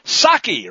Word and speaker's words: Psaki: history, reporters began to question the White House official spokeslier, Jen Psaki. Remember, the P is silent, just like in Resident, asking whether we should expect that kind of rhetoric to continue.